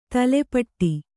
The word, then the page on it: ತಲೆಪಟ್ಟಿ: tale paṭṭi